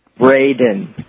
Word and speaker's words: Braden: Braden. – Braden. – R-A-D-E-N.